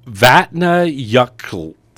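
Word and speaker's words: Vatnajökull: Vatna Yuktl. I'm reading the so no more beer. – It's spelled like Vatna Joe Cull, but the final sound is KTL put together.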